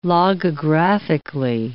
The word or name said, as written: logographically